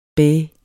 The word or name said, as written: bæh